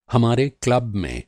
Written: क्लब

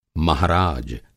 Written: महाराज

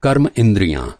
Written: इंद्रिय

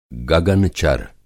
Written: गगन-चर

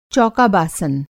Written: चौका-बासन

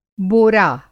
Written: बोरा

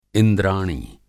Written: इंद्राणी

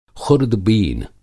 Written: ख़ुर्दबीन

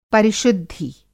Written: परिशुद्धि

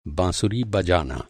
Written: बाँसुरी

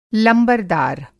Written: लंबरदार

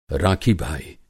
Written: राखी-भाई

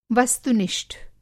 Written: वस्तु-निष्ठ

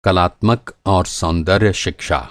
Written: कलात्मक

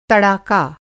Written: तड़ाका

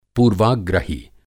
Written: पूर्वाग्रही

